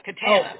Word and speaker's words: Katana: Rhymes with banana, Katana.